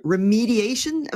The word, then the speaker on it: remediation: And apparently Enterprise Cloud Driven has made documentation remediation available. I don't know if I've pronounced that properly. Document remediation available? – Remediation. – Remediation, okay.